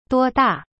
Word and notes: 多大: duo-da

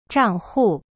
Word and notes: 账户: zhang hu

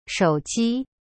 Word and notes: 手机: shou ji

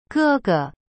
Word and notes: 哥哥: ge ge

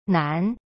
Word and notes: 难: nan

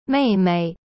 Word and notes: 妹妹: mei mei

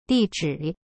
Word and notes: 地址: di-zhi

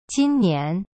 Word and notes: 今年: jin nian

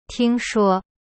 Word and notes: 听说: ting shuo

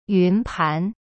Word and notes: 云盘: yun pan

